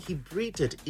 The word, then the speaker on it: breathed: Yeah, I need you to use the past tense. Like, if you want to say he breathed into man and God breathed into man, how would you say it? Pronouncing the word breathed well.